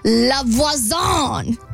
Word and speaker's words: La Voisin: So she meets this person named La Voisin.